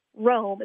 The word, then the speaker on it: Rhome: Meanwhile, there was a town two miles away, Rhome. It's spelled with an H.